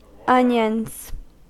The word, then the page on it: onions: American English